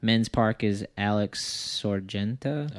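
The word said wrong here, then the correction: Sorgente: Oh, Sorgenti, yeah.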